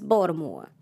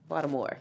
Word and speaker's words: Baltimore: She was like, It's not Baltimore. – It's Baltimore. – Baltimore, yeah.